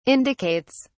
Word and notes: indicates: British English

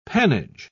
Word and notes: pannage: British English

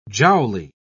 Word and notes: jowly: British English

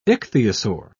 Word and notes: ichthyosaur: British English

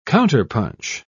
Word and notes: counterpunch: British English

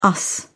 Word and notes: us: British English

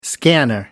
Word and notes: scanner: American English

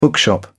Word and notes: bookshop: British English

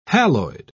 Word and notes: haloid: British English

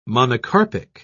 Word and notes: monocarpic: British English

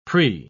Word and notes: prix: British English

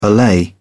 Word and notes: allay: British English

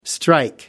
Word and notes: strike: American English

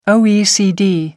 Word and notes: oecd: American English

